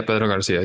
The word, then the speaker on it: Pedro García: Yeah, Pedro Garcia.